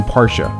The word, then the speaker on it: Porsche: 那第一句开头呢 This is, 这是一个简单的连读 This is the new 要重音一下 The new 是新款的 2017 Porsche,